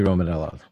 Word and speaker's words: Romanella: Rocky Romanella.